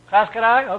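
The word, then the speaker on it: Clatskanie: How do you pronounce it? Klatskany? Is that how we pronounce it? Klatskany? Okay. Well, I guess the GPS girl was right. I was laughing at her because she was saying that. I said, that's not how you pronounce it. She was right.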